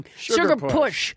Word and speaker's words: Sugar Push: Sugar Purse! – Sugar Push! Sugar Push! – Sugar Push! – I said it wrong. Sugar Push. It's a dance.